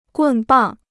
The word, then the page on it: 棍棒: gùn bàng